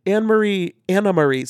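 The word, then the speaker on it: Anne Marie: She said it's spelled Anne, but it's pronounced Anna because it's a Dutch name.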